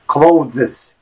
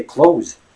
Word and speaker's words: clothes: thus making the pronunciation as clothes, right? But that's wrong. People just say close. It's as if you're saying closed door, right? So again, just forget about the th and imagine you're just saying close.